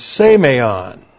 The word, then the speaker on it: semeion: It's semeion. Looks like this in the Greek. S, long E, M, E, I, O, N. Semeion.